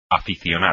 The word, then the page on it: aficionar: espagnol